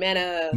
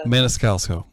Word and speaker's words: Maniscalco: Mana Maniscalco.